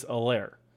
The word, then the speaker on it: Helaire: Let's talk about uh, it's a silent H, right? It's not Elair, it's Elair.